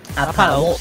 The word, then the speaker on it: Apatow: Jude Apatow, no sé cómo se dice esto. Jude Apatow, sí.